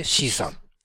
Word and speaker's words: CSUN: CSUN と書いて C さんと呼ばれているカンファレンスです。